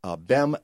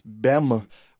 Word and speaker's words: Bem: It's not Ben with an N. It's Bem with an M. Bem.